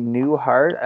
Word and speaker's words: Newhart: And we're back, guys, with Natalie Newhart. I hope I'm pronouncing your last name correctly. Is that right, Natalie? – That's right. Just like Bob Newhart.